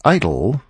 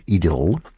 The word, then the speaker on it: idyll: An idyll, or if you prefer the English pronunciation, an idyll.